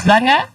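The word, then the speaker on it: Lwanga: ¿O será L o será I? – Luanga. – ¿Cómo sabes pronunciar estas cosas? San Carlos Luanga, es San Carlos. – Pero, Murillo hubiera dicho Wanga. – Ajá, así, oiga, pues es que sí, bueno, es la L, el Wanga.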